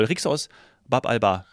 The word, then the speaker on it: Rixos Bab al-Bahr: Rixos Bab al-Bahr.